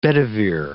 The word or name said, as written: Bedivere